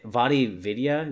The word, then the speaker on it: VaatiVidya: Vaadi Vidya, VAATIVIDYA.